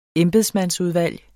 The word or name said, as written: embedsmandsudvalg